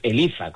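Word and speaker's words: ELIZA: Además está bien pronunciado con Z, aunque yo viva en Cádiz, no es que sea Elisa y yo le llame Elisa, que ya me lo han dicho por ahí, no, es Elisa con Z.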